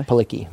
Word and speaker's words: Palicki: How about Adrienne Palicki? Am I saying that properly? – Palicki. – Palicki? – Yeah, she's cool.